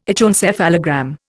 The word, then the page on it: echoencephalogram: Indian